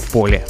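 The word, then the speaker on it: Pole: Это Стефан Бетке, известный как Пол. Мы привыкли обычно его произносить как Поле.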